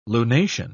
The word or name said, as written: lunation